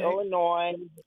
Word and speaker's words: Illinois: But then it is Illinoisans, and people in Illinois, and then the S is not pronounced. It's okay. – Illinois.